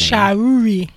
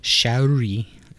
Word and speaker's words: Shauri: Shaori. – I don't know. I put it in my notes later. – It's like phonetically. S-H-A-U-R-I. – Phonetically, yeah. – Shaori.